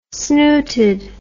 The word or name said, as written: snooted